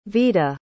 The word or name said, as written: vida